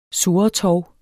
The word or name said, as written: surretov